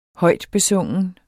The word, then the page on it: højtbesungen: -beˈsɔŋˀən